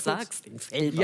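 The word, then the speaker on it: Velbert: Es gibt aber so viele Leute sprechen das falsch aus, weil wir auf der anderen Rheinseite sind, die denken, das ist schon so weit an Frankreich, dass man das... – Felbert? – Ja. Ja. So viele Leute wollen das immer so aussprechen, deswegen, ja. Du hast das genau richtig ausgesprochen.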